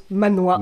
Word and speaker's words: manhwa: D'accord, on dit manhwa. – Alors, je ne sais pas si c'est comme ça que ça se dit, mais en tout cas, M-A-N-W-H-A, d'accord. – Je prononce manhwa.